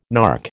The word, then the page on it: nark: American English